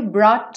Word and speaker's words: brought: for all the joy you brought to my life. Lembra que eu falei aqui do GH que não é pronunciado? Então, brought, brought to.